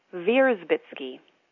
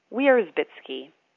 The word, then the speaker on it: Wierzbitsky: The name Wierzbitsky originates from East Prussia, which is now Poland, and when I visit Germany, people pronounce it Wierzbitsky. Here in the U.S., we don't pronounce W's like V's, and so we say Wierzbitsky.